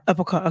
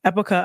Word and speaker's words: Epoca: Epoca. – Okay. Epoca, a nonprofit seeking to increase the visibility and representation of BIPOC actors and storytellers in Utah.